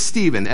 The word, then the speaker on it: Stephen: And whatever age I was in seventh grade, 13 or something, 13-year-old me all of a sudden realized that my name just got mispronounced. Now, my name is Stephen, S-T-E-P-H-E-N.